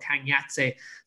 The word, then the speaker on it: Kangyatse: Kangyatse. So it's spelled K-A-N-G and then a separate word Y-A-T-S-E. So Kangyatse out in India.